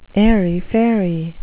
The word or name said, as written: airy-fairy